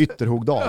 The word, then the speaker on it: Ytterhogdal: Ställs i premiären mot Härjedalens jävla stolthet Ytterhogdal. Jag vet inte ens om jag uttalade rätt. Ytterhogdal.